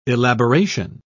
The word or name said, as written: elaboration